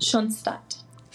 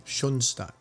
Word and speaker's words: Schoenstatt: Schoenstatt. – Schoenstatt. Okay. – The English and Spanish pronunciation. Technically, it's in German. It's like Schoenstatt, but we don't really, you know, bother with that in English.